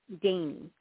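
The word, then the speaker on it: Dani: Dainey, like Danish or dainty. Dainey. Dainey Marie. – Can you spell your first name? – Yes. It's D as in David, A, N as in Nancy, I. And it's a long A.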